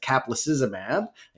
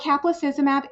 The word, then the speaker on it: caplacizumab: So what caplacizumab is, and you pronounced it correctly, is a monoclonal antibody fragment that binds the von Willebrand factor and prevents its interaction with platelets.